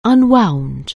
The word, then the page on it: unwind: anglais